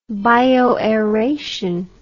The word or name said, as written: bioaeration